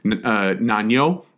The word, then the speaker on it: Nanyo: Yes, as I sort of let slip a minute ago, they referred to it as the South Seas, Nanyo, the Japanese pronunciation of the Chinese characters for Nanyang, this literally the South Seas.